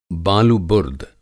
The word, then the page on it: बालुबुर्द: بالوبُرد